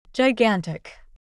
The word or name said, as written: gigantic